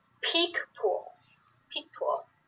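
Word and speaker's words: Picpoul: One of the things, it's cool how to pronounce it, Peak Pool is the right way to pronounce the grape variety.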